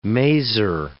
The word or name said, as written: maser